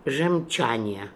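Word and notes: Gemchania: Russian

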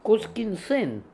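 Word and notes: Canineson: Russian

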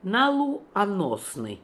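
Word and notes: Naloo osnian: Russian

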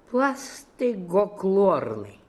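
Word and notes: Blastegoklornian: Russian

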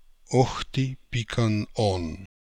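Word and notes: Okhti Pikan On: Russian

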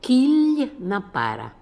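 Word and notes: kilnapara: Russian

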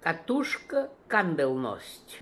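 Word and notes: Bobbinkandelnost: Russian

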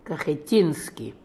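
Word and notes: Kakheteenian: Russian

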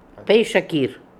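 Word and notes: Paischakir: Russian

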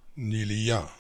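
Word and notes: Nilia: Russian

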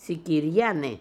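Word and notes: Sikirians: Russian

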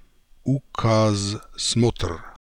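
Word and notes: Ookazemotra: Russian

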